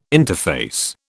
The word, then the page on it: interface: British English